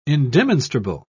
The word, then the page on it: indemonstrable: British English